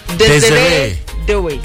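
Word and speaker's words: Désiré Doué: Desiree Doué.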